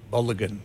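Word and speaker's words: Balogun: Bowler Gun? Is that how you pronounce his name? – Bulligan. Yeah.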